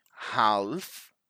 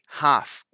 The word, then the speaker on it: half: We don't say half, not half. We say half. We don't actually pronounce that L.